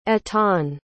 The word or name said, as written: etan